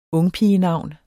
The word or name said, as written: ungpigenavn